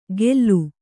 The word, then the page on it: ಗೆಲ್ಲು: Kannada